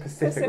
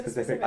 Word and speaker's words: specific: If you said Pacific rather than specific, I hope you find toast in your like butter.